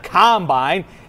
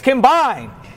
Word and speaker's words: combine: It's not combine, it's combine. A combine, you see, I looked this up. A combine is a farming machine.